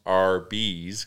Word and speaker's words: Arby's: About how Arby's, spelled A-R-B-Y-S, is pronounced R-B's, because that's how you would spell R-B's, and Arby's for roast beef.